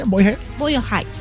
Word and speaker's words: Boyle Heights: Boyle Heights.